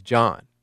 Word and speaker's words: John: I mean, I should know all about silent H's, considering that my name is John, right? What's the H? What's the purpose of that H in my name? It's not Jahan, like Jahan Dotson.